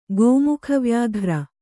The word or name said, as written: ಗೋಮುಖವ್ಯಾಘ್ರ